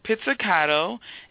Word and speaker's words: pizzicato: Yes, it's Pizzicato.